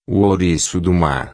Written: ourico-do-mar

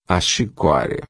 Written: chicoria

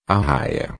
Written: arraia

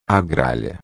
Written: gralha

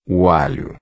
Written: alho

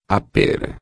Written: pera

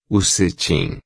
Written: cetim

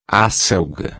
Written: acelga

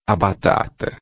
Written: batata

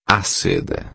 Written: seda

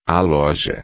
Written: loja

